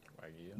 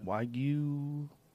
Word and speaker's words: Wagyu: I mean, it's white, and I think that's Wagyu. How do you say it? – Wagyu. – Wagyu.